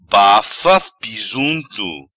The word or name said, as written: Bafa pisunto